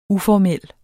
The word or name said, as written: uformel